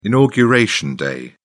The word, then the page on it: inauguration day: British English